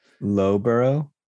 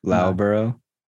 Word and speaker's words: Loughborough: Lowborough? Loughborough? – No. It's not pronounced like Slough, weirdly. That first L-O-U-G-H, pronounces a different... It gives off a different sound.